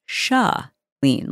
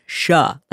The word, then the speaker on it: Chalene: My name is Chalene, like S-H-U-H, Chalene, like as in huh, as in like Chalene Dion. Think about that, like Chalene Dion, or I don't even know how to help people pronounce my name, but all I know is it is not Shay, and it is not Char, it is Chalene, like shut the door, Chalene.